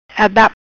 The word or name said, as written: Adap